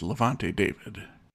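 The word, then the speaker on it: Lavonte David: Is it Levante David? – Yes, it absolutely is Levante David. – It was Jonathan's episode. That's right. – And Jonathan did come up with Levante David in that game.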